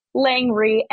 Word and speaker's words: Langree: Name is spelled Cecilia, C-E-C-I-L-I-A, Langree, L-A-N-G-R-E-E, although I'm sure it'll be in the notes for this show as well.